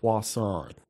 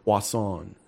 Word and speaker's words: Poisson: It's Robert Poisson. – Poisson? – Poisson. – Oh, is he like French? – It's kind of like French, yeah.